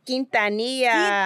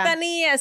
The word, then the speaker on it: Quintanilla: It's actually Selena Quintanilla. Quintanilla. – Quintanilla.